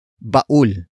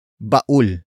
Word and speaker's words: baul: Baul. Baul. Baul is spelled as B-A-U-L.